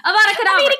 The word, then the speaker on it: Avada Kedavra: And you're reading it, and when you hear Avada Kedavra or whatever, or Adavra, I'm saying it wrong. Avada Kedavra.